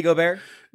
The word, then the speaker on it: Gobert: It rhymes with sorbet. You nailed it. Anyway, Nate, you were saying about Rudy Gobert?